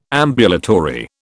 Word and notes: ambulatory: British English

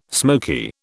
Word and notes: smoky: British English

